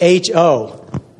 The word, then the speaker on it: Ho: It starts with H-O. How do you pronounce that? Ho! That's what's happening in Isaiah 55.